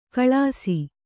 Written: ಘಳಿಸ

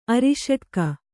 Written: ಅರಿಷಟ್ಕ

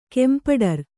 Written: ಕೆಂಪಡರು

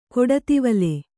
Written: ಕೊಡತಿವಲೆ

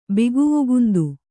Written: ಬಿಗುವುಗುಂದು

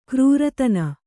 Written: ಕ್ರೂರತನ

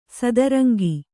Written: ಸದರಂಗಿ

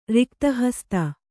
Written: ರಿಕ್ತಹಸ್ತ

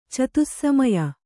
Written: ಚತುಸ್ಸಮಯ

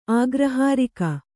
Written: ಅಗ್ರಹಾರಿಕೆ

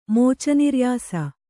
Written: ಮೋಚನಿರ್ಯಾಸ

